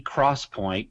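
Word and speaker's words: XPoint: Essentially, it's called 3D Crosspoint, but they spell it X Point.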